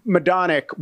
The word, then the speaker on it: Majdanek: Well, for instance, Majdanek, which is spelt weird in English, right? – Well, it's Polish, so it's M-A-J is my.